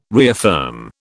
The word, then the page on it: reaffirm: British English